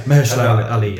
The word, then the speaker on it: Mahershala Ali: Yeah, Mahershala Ali.